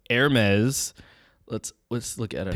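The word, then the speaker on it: Hermès: So for those that that know of Hermes, it is pronounced Hermes, not Hermes. There's no huh there. It's a air with the ER.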